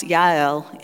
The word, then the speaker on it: Jael: And there was a Kenite woman there named Jael, and it's pronounced Yael in Hebrew, but I'm an English speaker, so I'm going to say Jael. And she greets Sisera. Verse 18, Jael went to meet Sisera and said to him, "Come, my lord, come right in.